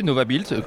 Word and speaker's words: Nova Build: Alors vous êtes président de Nova Build, c'est comme ça qu'on prononce ? – Oui, tout à fait, Nova Build, cluster de l'éco-construction des Pays de la Loire.